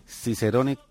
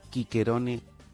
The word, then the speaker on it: Cicerone: Lo cierto, nos dice el autor de esta web, es que la letra C en latín se pronunciaba siempre como una K, independientemente de la vocal que le siguiera. Así pues, la expresión del consulado de Cicerón, aunque nosotros digamos Cicerón, sería Quiquerone Cónsule.